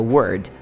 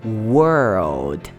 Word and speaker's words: world: Muita gente tenta pronunciar o mundo e fala palavra, que é word. Na verdade, ó, world. Pensa que tem duas palavras dentro dela, were e o old. E aí você pronuncia conexa, ó.